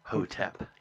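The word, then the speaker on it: Nyarlathotep: So we're trying to pronounce the Egyptian part correctly, and that's Hotep, not Thotep. So yeah. Narlethotep.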